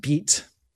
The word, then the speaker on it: bite: Bite, B-I-T-E, in French is pronounced beat.